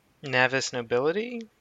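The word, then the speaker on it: Navis Nobilite: That's how they say it in the black book, or the uh, black library books is Navis nobility.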